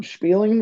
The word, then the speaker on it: Spieling: Spieling, how do you like to say it? Spieling the Beans? – Been told Spieling. – Yeah, Spieling is the Yiddish pronunciation, I think, or the German is to spiel, to play.